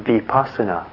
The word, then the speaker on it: vipassana: The Buddhist word is vipassana, which is spelled V-I-P-A-S-S-A-N-A.